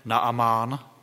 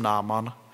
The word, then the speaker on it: Naamán: Když jsem četl ten text, tak jsem se to snažil vyslovovat tak, jak to tam je, Naamán. Nevím, jestli jsem se nepřeřekl a neřekl jsem Náman chvílemi. V kázání budu toho muže nazývat Náman, tak jsme se to zvykli vyslovovat v besídce, tak je to v kralické Bibli, a zjistil jsem, že i nové překlady se k tomu vracejí.